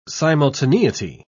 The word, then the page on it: simultaneity: British English